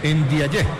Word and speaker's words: Ndiaye: Ndiaye, sí, señor. La camiseta tiene Ndiaye. Ndiaye, porque tiene acento en la A.